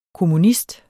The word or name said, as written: kommunist